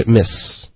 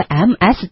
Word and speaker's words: Ms.: 对，我见到的更常见的呢，我觉得也许是一个美式的发音，就是 miss，然后 mrs 呢就是 MRS 或者会写 MISSES 这样全部写出来，它也有两种发音，它就是 Mrs. 或者 Mrs. 都行，无所谓。 – 那这个 MS 点儿会发成美式发音会发成 Miss，可是你也会听到美国人发 Ms. 用一个 z 的音，也会见到的，可是就比较没那么常见。